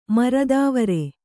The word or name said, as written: ಮೂರ್ತಿವೆರು